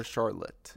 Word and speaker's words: Charlotte: Am I saying it right? Charlotte's or Charlotte? – Yeah, Charlotte. Yeah. Like, you know, like Charlotte's Web? – Yes.